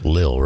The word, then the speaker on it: Lil: You know it's Lil, right? – Lil, L-I-L. You it's like 50 Cent. – All right. So you're, you know, right. you gotta pronounce it right.